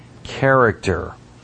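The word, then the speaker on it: character (χαρακτήρ): The Greek word translated image in the verse is pronounced character.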